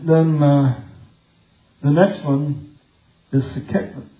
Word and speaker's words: Sekhmet: S-E-K-H-M-E-T. And so I try to pronounce all those as they're really there. I could take the H out because in Spanish and several languages, the H is silent. So we could say it, Sekhmet.